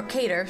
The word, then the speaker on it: Qatar: A request by Zelensky to share a message of world peace before a kickoff at the World Cup final in Qatar last December was also rejected or Cater, sorry. And Cater last December was rejected by FIFA, it was reported. That's how you say it. Cater.